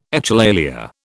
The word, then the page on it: echolalia: British English